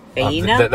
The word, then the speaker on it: Faena: How do you say it? Faena? – That's the name of the hotel, but yeah. – The restaurant is at Faena, F-A-E-N-A.